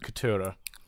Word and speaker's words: Ketura: She says the stress is on the middle syllable, Ketura.